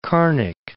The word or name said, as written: Carnic Alps